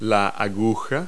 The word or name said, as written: spire